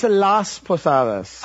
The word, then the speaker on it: Las Posadas: It's Las Posadas. – Oh, I'm sorry. – Las... – I forgot the S.